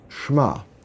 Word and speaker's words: Shema: and that's a word that's pronounced very similarly It's pronounced Shema, and it's spelled Shin Mem Ayin.